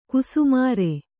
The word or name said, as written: ಘಸ್ಮರ